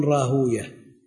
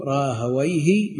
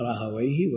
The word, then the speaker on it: راهويه: أهل الحديث يقولون راهوية، إسحاق ابن راهوية، وأهل العربية ينطقونه راهويه مثل سيبويه ونفطويه. المحدثون يمنعهم من نطقه هكذا بويه، ختمه بويه يمنعهم. ما جاء في بعض الآثار أن ويه اسم شيطان، فالخبر لا يصح، والعبرة في هذا بأهل العربية، فينطق راهويه وسيبويه ونفطويه وهكذا.